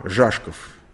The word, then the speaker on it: Жашков: Жашков. Это ударение на А. Жашков.